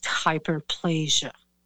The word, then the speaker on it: hyperplasia: it was assumed that was just hyperplasia.